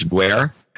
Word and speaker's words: Guerre: $100 from Francis Guerre. Square. It rhymes with square. It's Guerre.